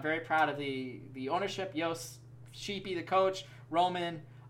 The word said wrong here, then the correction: Romain: Romain, how his name is actually pronounced, and all the players.